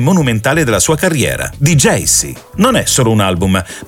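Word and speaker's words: Djesse: Il titolo di Jaycee è una pronuncia fonetica delle sue iniziali, JC, Jacob Collier, un modo per dire che quest'opera è la summa del suo universo artistico.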